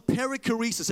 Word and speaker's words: perichoresis: It's called the perichoresis. Everyone say perichoresis. Now say it like you're a little bit excited. Perichoresis. Right, peri meaning circle. Choresis meaning choreography. Right? So when you want to explain the Trinity, this is what you say.